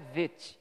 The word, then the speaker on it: Bet: A pronúncia correta é Bet, com V, tá?